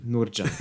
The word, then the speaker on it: Nurcan: Nurcan, ist das richtig ausgesprochen? Fuck. Ich scheue mich immer davor. Wie lange kennst du meine Mutter und du kriegst es immer noch nicht geschissen, ihren Namen richtig auszusprechen? – Nurjan. Nurjan, ganz liebe okay, Grüße ja.